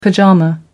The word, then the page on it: pyjama: American English